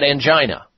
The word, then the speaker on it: angina: That would be angina. Some people pronounce it angina.